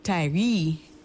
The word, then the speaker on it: Tyre: It should probably be pronounced Tyree.